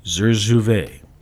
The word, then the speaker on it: Zurzuvae: Let's see if I can pronounce this. The pill, which will be marketed as Zurzuvae, Z-U-R-Z-U-V-A-A-E. Okay, Zurzuvae. It consists of a synthetic version of a brain steroid that regulates stress.